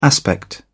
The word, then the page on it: aspect: British English